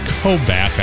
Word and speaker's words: Koubek: It's spelled Kubek, K-O-U-B-E-K, but it's pronounced Kobeck, I guess.